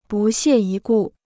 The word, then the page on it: 不屑一顾: bù xiè yī gù